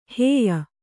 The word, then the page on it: ಹೇಯ: hēya